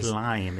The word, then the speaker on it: Lyme: I still can't remember if it's Lyme Regis or Lyme Regis. – I think it's Lyme, isn't it? – I think it's Lyme, but it's spelled with a Y, I think.